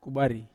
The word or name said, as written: kubari